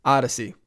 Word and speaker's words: Audacy: That's spelled A-U-D-A-C-Y, Odyssey.